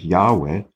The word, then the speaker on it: Yahweh: And so Yahoh, which might well have been the original pronunciation of the name that is nowadays by most scholars pronounced as Yahweh. Yeah, there is, the case for the pronunciation Yahweh is not very strong, in my opinion, in fact.